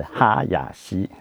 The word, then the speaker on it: 林: 虽然当他任命了呃第二次那个改组的时候，唯一任命的是林方正，那个林念哈亚西，不是念不是小林的林哦，哎不是念林，不是 L I N，是哈亚西，呃日文里面特有的一字音一母音的发音，呃超级超级日本式的名字哈亚西，呃林方正，呃，方念 Yoshi，正念 Masa，还有 Yoshi Masa。